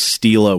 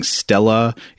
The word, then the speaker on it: Stela: And I already apologize because I know at some point in this episode I'm going to forget to pronounce Stela with a long E and I'm going to start saying Stella.